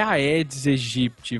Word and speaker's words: Aedes aegypti: Porque Aedes vem do grego, que quer dizer odioso, e em grego se pronuncia Aé, e Egipte, de A Egipte, vem do latim, onde não se pronuncia o AE.